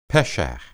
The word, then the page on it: pesha: Hebrew